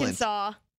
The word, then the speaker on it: Arkansas: When is S silent? – Arkansas. – Oh, yeah. Arkansas.